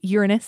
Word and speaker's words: Uranus: Yes, it is pronounced Uranus.